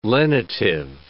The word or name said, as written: lenitive